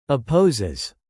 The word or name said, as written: opposes